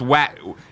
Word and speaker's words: Wattam: Wadham reviews are out. How do you say this? I already got corrected by an antibiologist. – Know. – He says it's Wattam.